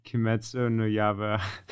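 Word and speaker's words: Kimetsu no Yaiba (鬼滅の刃): I'm going to probably butcher every name and every pronunciation in this episode, but Demon Slayer movie, Kimetsu no Yaiba movie, Mugen Train.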